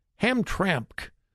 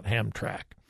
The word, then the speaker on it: Hamtramck: Hamtramck. I've been pronouncing it wrong. I think it's H A M T R A M C K. Hamtramck, I guess, not Hamtrack.